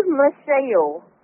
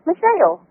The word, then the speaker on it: Michele: Michelle. – What is it? – Michelle. – Sybil? – No, Michelle. M-I-C-H-E-L-E. – Spell it one more time. – M-I-C-H-E-L-E. Michelle.